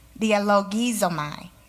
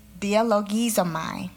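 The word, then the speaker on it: Dialogizomai (διαλογίζομαι): I got on Google, and I looked up how to say it. Let's see if I can remember. Dialogizomai, that's it. Dialogizomai,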